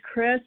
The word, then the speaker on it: Kris: And so, my name's Chris. I spell it K-R-I-S.